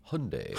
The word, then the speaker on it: Hyundai: Hyundai, how do you say that? Hyundai.